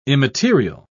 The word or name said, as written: immaterial